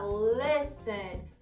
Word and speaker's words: listen: Então, por exemplo, escutar, a gente acaba falando listen ou listen, mas na verdade, nesse caso, o som específico é um som mais de E, então fica listen. Lembrando que o T é mudo, listen listen.